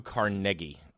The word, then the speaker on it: Carnegie: Who was the first person to reach a net worth of Andrew Carnegie, which is the proper way to pronounce his name, despite the fact that everybody says Carnegie.